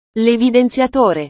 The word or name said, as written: levidenziatore